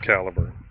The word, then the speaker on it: Calibre: I know it's it looks like it's spelled Calibre. It's actually pronounced Caliber.